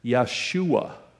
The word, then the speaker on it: Yeshua: It would have Jesus' name in the New Testament time period would have been pronounced Yeshua. You guys, there may be somebody in here that has that exact same name, whether you realize it or not. They didn't say the J sound.